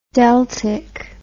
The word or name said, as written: deltic